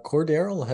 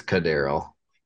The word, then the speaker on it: Cordarrelle: We were talking about Scotty being that 3rd receiver and that slot guy, but Cordarrelle has played good for the Falcons, so it's not surprising that he's moved himself up on this depth chart. – It's Cordarrelle. – I'm bad with pronouncing.